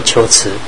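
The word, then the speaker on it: 龟兹: 啊，二十岁以后常住在秋池，啊，我们这些就是所谓的库车了啊，念作秋池。前秦建元十八年九月，苻坚遣骠骑将军吕光西发秋池及乌齐，秋池被攻破啊。罗什也就离开秋池到东方来。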